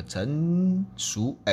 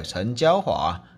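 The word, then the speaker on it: 陈椒华: 那在他这个六十七集呀，讲到这个假债利用真污染，这个陈淑哎陈娇华。我每次都会念成熟，哎，这个陈娇华大立委啊，那讲这个学学甲这个卢查案，财法手段不足又官官相护，那这是他的题目啊。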